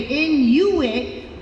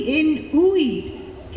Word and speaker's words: Inuit: Sometimes people say Inuit, but it's not like you're playing tag, not Uit, it's Ui, Inuit.